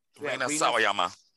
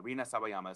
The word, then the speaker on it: Rina Sawayama: Rina Sawayama. – Sawayama. Rina Sawayama.